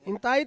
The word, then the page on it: intai: Ansus